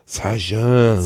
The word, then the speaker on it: Sagem: Sagem.